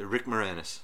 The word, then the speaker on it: Moranis: And he also reminded me of Rick Moranis. Moranis. I can never say his name right. You say it. – Rick Moranis.